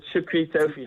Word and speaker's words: Shukri Toefy: Yes, it's Shukri Toefy.